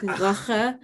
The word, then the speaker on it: Lachen: No, they have the softer G, so everything is gewoon lachen en graf.